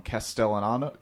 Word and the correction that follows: Castellanos: I can't do it. – Castellano. – It's Castellanos.